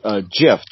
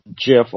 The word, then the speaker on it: GIF: So, the word that we come up with is GIF, G-I-F, which is an acronym for Graphic Interchange Format. The way it's pronounced is a little bit controversial. They go with the more common GIF, although I guess it could be what GIF too is a possibility. – Yeah, a lot of people are in the GIF camp because the first word is graphic with a hard G, so that was where it came from. But the inventor of it says it's GIF.